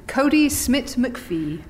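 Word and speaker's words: Kodi Smit-McPhee: Cody Smit McPhee.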